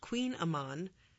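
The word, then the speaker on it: Kouign-amann: Oh, and the Kogan Amman, which the author then notes is pronounced Queen Amman, pastries are a delicious simplicity.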